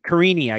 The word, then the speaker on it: Karine: Karine Silva wins inside the distance, even money. – All right, I'll take plus 100. I'll take that. ITD, Karini, I guess actually is how I should say her name.